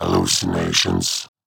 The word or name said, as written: Hallucinations